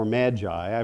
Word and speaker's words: magi: Uh, this word magi or magi, I've heard it pronounced both ways.